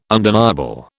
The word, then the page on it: undeniable: British English